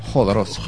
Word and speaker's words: Jodorowsky: Creo que Jo se pronuncia como en Jorge, así que por eso le dije Jodorowsky.